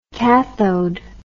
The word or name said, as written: kathode